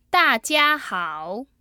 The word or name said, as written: dajiahao